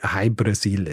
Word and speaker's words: Hy Brasil: Jahrhundert jetzt, wenn in Irland drüber geschrieben wird, dann wird gerne mal die Insel zum Beispiel Breasil geschrieben oder O Apostroph Brasil oder eben Ui Breaseil, was ausgesprochen quasi so viel wie High Brasil ist.